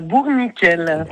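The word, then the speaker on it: Bourniquel: Elle habite à Bourg-Niquel, c'est comme ça qu'on prononce? – Tout à fait, à Bourg-Niquel.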